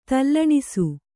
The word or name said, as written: ತಲ್ಲಣಿಸು